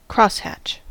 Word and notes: crosshatch: American English

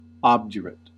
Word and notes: obdurate: American English

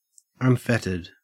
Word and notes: unfettered: Australian English

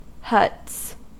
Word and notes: huts: American English

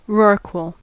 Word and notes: rorqual: American English

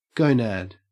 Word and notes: gonad: Australian English

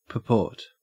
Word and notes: purport: Australian English

